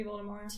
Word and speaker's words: Voldemort: It's supposed to be Voldemort. – T is silent. French.